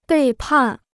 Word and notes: 背叛: bèi pàn

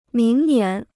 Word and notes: 明年: míng nián

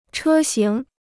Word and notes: 车型: chē xíng

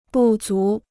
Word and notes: 不足: bù zú

